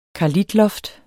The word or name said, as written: karlitloft